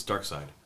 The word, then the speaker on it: Darkseid: The way it's spelled S-E-I-D. – Yeah. – It says, you think, no, but it. – It's Darkseid? – Yeah, it's Darkseid. – Yeah. – It's like Saeed.